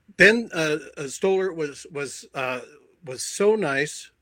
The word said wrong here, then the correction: Stiller: Did I get the name right? – Ben Stiller. Sure.